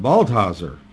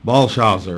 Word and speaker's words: Belshazzar: But the biblical account in David states that Baltazar, or Belshazzar, or these are different pronunciations, was the last Babylonian king.